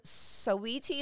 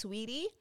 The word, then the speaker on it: Saweetie: And the fourth one, her name is rapper Saweetie, is how it was spelled, but apparently it's pronounced Sweetie.